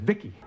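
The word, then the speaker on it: VICI: V-I-C-I, I pronounce it Vicky.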